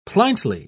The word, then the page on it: pliantly: British English